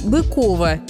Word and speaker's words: Быково: Во-первых, это старинный, ныне недействующий аэропорт Быково в Московской области. Да, правильное ударение здесь именно на второй слог, и никак иначе.